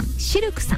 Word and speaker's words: CILQ: そして南青山にフレンチレストランシルクさん、 CILQ と書いてシルクさん、そ